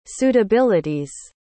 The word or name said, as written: suitabilities